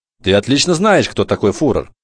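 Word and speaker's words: Führer: «Кто такой Фюрер?» – спросил Бруно. – «Ты неправильно произносишь», – сказал отец и выговорил слово медленно и правильно, специально для того, чтобы сын повторил за ним. – «Фюрер», – повторил Бруно, стараясь в точности подражать отцу, увы, безуспешно.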